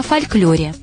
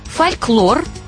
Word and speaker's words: фольклор: Кстати, о фольклоре. Два варианта произношения. Фольклор, л твердый и фольклор, л мягкий. Равнозначно. Равноправные варианты – фольклор и фольклор. Выбираете вы.